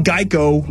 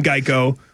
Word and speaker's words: Geico: The original idea was that a gecko could help people remember the name Geico, which often got pronounced like gecko.